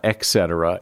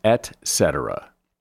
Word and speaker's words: et cetera: You often hear people say et cetera, but it's not et cetera. It is et cetera.